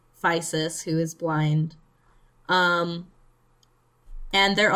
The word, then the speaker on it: Physis: Where he learns from Soldier Blue and this blind oracle they have named Physis, who, looking at the katakana, her name is actually supposed to be pronounced Physis, but I'm not doing that.